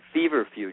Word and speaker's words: feverfew: Feverfew, just like a fever, cold headache fever, F-E-V-E-R, and it's all one word, feverfew, F-E-W, and you can Google it.